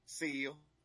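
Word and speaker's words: Seale: Seal.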